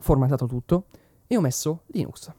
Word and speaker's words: Linux: Il prossimo argomento è Linux e perdonatemi se l'ho detto con questa pronuncia orribile inglese, intendevo dire Linux, detto così in italiano.